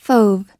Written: fauve

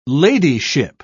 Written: ladyship

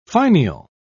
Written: finial

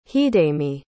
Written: hidemi

